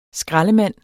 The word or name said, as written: skraldemand